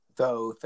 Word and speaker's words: Thoth: It's just Thoth. It's T-H-O-T-H, so it's Thoth, right? I just, that's the way English was taught to me, so that's the way I pronounce it.